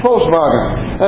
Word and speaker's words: Volkswagen: German pronunciation, Volkswagen.